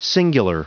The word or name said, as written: singular